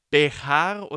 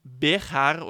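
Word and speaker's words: Béxar: Und ähnlich ist es mit Bejar oder Bexar. Im Spanischen schreibt man es mit J und da wird es Bejar ausgesprochen, aber der Ch-Laut ist im Mexikanischen ein X.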